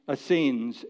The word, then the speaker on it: Essenes: They were the Zealots and the Essenes, E-S-S-E-N-E-S, you might pronounce it Essenes.